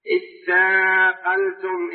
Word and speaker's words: اثاقلتم: اثاقلتم إلى الأرض، اثاقلتم أصلها تثاقلتم، تثاقلتم تاء وتاء، فأدغمت التاء في التاء لتقاربهما، وجيء بالهمزة من أجل التوصل إلى النطق بالساكن، لأن الحرفين إذا أدغما فعند البدء بهما لا يتسنى إلا بإتيان حركة، إتيان بهمزة، وتسمى همزة الوصف.